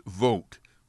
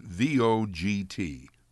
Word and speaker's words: Vogt: Fritz Vogt, which is spelled V O G T, Fritz Vogt, slept in barns and relieved the pain of rheumatism with alcohol.